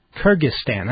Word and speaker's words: Kyrgyzstan: Kyrgyzstan, that's how you say it. Well, some people say it differently, but that's how I say it.